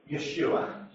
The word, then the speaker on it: Yeshua: So if you want to be pedantic, the proper way to say the name of Jesus is to say Yeshua.